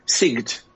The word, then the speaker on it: Sigd: How do you pronounce it? Sigd. – Sigd. Sigd. Right.